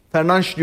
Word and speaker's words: Fernandes: Fernandes diye okunur bu arada Portekizce'de, onu da belirteyim.